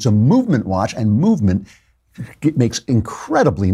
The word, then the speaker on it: MVMT: It's spelled MVMT, so you don't have to pay for all those expensive vowels.